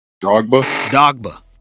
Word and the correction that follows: Drogba: He ends up meeting Didier Dogba. – Drogba. Look, that's a legend.